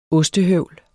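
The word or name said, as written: OSTEHØVL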